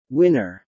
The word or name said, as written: winner